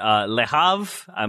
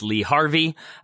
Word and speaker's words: Le Havre: I should mention one more in France that just kind of flies under the radar, but Le Havre. My French is not great. If you want to go with the Wire's pronunciation, it's Lee Harvey. That is definitely not what it is.